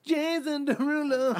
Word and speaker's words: Derulo: It's pronounced Jason Derulo.